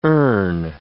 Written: earn

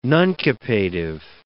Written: nuncupative

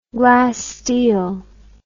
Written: glassteel

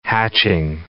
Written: hatching

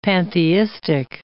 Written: pantheistic

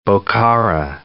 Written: Bokhara

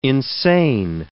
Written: insane

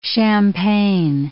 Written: Champaign

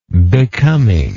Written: becomingness

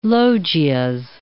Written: loggias

